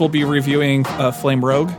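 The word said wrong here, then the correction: Flamme Rouge: Flame Rouge? – Yes, that one.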